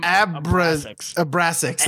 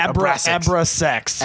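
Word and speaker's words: Abrasax: So speaking of worst supporting actor, Eddie Redmayne won for Jupiter Ascending as Balaam Abrasex. That's how you say it. – Abrasex.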